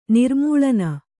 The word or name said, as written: ನಿರ್ಮೂಳನ